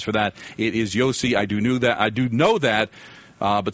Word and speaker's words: Josi: He says, Don, you've been calling Roman Josi Yoshi for weeks. It's pronounced Yossi.